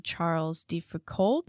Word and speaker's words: Charles de Foucauld: And then finally, the prayer of Blessed Charles de Foucauld.